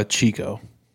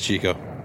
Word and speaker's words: Ciccio: It's C-I-C-C-O. I've gotten psycho. I've gotten sicko. I've gotten kicko. I never get... The only people that pronounce it right are foreign language teachers. It's really Chico. – It's Chico, yeah.